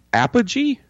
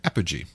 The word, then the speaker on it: apogee: So, how did you say that? – Apogee? – Apogee, okay. Which had created effects for Battlestar Galactica as well.